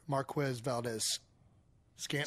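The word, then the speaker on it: Marquez Valdes-Scantling: Anyway, Cisco made another violent hit on Chiefs wide receiver Marquez Valdez-Scantling. – Scantling, yes. – Gosh. – You said it right.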